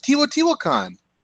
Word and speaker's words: Teotihuacan: Teotihuacan, sure. – Yeah. – Yeah. Well, that's a very famous place. Teotihuacan is where the big pyramids are.